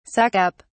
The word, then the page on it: suck up: British English